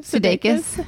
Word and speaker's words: Sudeikis: Sudeikis. – Have you heard anything from him after your just shout out directly into the screen? The camera lens. – They were like, they said, Jason Sudeikis is watching right now.